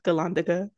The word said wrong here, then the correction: gondola: So I'm like, I don't think that's right. I could see you were like really making sure you were saying it right. – Gondola. Gondola. There you go.